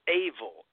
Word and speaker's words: Avil: My name is Avil, A-V-I-L, like the accentuation of evil.